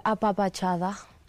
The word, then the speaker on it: apapachada: Bien, me siento eh, apapachada. – Se dice así.